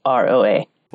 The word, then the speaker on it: Figueroa: And my last name's Figueroa. It's really hard to pronounce. It took me a while too after I got married. So it's Figueroa.